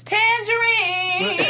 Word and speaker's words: Tanjareen: And you know the correct way to pronounce my name is Tanjareen. And you can find me on @OfficialTanjareen on Instagram and Facebook, and just @Tanjareen on everything else, from YouTube to Snapchat to Twitter to Periscope.